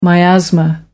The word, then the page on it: miasma: American English